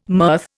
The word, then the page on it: mirth: Indian